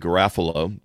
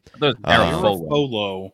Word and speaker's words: Garafolo: Mike Garafalo, I call him Garafalo, but that's not how you say his name. – Garafolo. Uh, Garafolo.